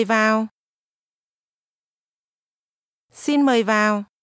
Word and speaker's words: vào: Vào. – This word is pronounced with a falling tone.